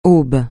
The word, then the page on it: aube: francais